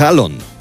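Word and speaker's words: Haaland: a decir el nombre real de Erin Halland, que es Halon. La doble A en los idiomas escandinavos se pronuncia como una O.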